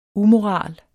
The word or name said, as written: umoral